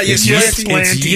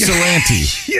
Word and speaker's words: Ypsilanti: It's not Ypsilanti. – It's Ypsilanti.